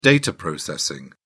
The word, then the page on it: data processing: British English